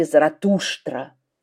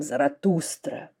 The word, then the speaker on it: Заратуштра: В Персии его называли Заратуштра. Иногда мы произносим Заратустра. Греки называли его Зороастр.